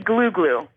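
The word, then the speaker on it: glou-glou: What about, is it glue glue or glau glau? – It's apparently glue glue. – Glue glue.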